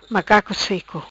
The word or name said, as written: Macaco seco